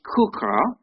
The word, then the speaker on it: chuqqah: This one is called, actually it's an unusual pronunciation, it's called Kuka. And the spelling is C-H-U-Q-Q-A-H.